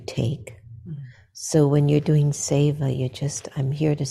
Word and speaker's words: seva: It's either Siva or Seva. – Seva. – Seva. – Yeah, keep doing seva, which is selfless service.